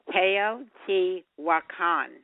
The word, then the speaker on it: Teotihuacan: So I think I even learned how to pronounce it during the show Teotihuacan. How's that? – You got it.